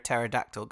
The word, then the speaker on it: pterodactyl: Why can't you hear a pterodactyl go to the toilet? – I don't know. – Because the P is silent.